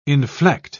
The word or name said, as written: inflect